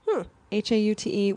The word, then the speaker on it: haute: Haute, which is really Haute. Part of like haute couture kind of.